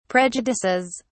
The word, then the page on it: prejudices: British English